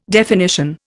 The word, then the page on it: definition: Indian